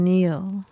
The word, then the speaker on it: kneel: How are you spelling Neil? – K-N-E-E-L, Neil.